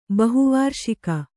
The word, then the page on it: ಬಹುವಾರ್ಷಿಕ: bahu vārṣika